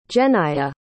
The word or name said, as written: genaya